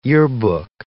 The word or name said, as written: yearbook